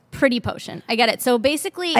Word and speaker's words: PRTTYPEAUSHUN: One is first of all, I'm going to say, first and foremost, it took me like 2 years to figure out how to pronounce this. It's PRTTYPEAUSHUN. Pronounced Pretty Potion. Hate the name. Love the product. – Pretty Potion.